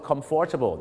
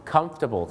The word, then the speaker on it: comfortable: หลายคนก็อาจจะออกเสียงว่า comfortable ซึ่งก็มีฝรั่งบางคนนะครับออกเสียงว่า comfortable นะครับแต่จริงๆแล้วนะครับถ้าถูกต้องเลยนะฮะเขาจะออกเสียงว่า comfortable ครับ comfortable ไม่ใช่ comfortable นะครับ